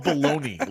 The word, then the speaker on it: bologna: it's even called baloney. Like what the fuck? Like that G is silent.